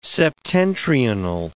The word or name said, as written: septentrional